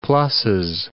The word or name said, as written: pluses